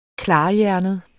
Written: KLARHJERNET

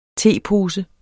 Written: tepose

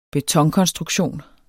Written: betonkonstruktion

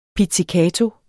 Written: pizzicato

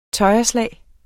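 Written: tøjreslag

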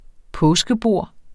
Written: påskebord

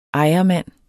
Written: ejermand

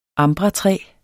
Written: ambratræ

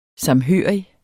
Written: samhørig

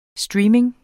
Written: streaming

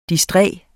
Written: distræt

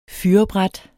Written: fyrrebræt

